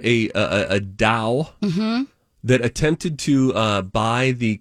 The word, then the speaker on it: DAO: The group that tried to buy the Constitution formed a DAO, D-A-O, yes. pronounced like D-O-W.